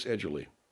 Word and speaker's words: Edgerly: It's Chris Edgerly.